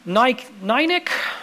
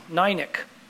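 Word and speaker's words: Nikic: I want to tell you a story of a young man, Chris Nynick, I think's how you'd pronounce it. It's spelled N-I-K-I-C. N-I-K-I-C. Nynick.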